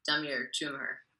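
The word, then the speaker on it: Damir Džumhur: Well, let me just say that was excellent pronunciation of Damir Djumhur. – We have practiced this on the show before, so I'm really anal with my pronunciations. I have to get them right, so I'm fairly, like, I try and make it work when I can. – That's so, well, as an MC, pronunciations are everything, and that is a name I don't hear pronounced correctly often.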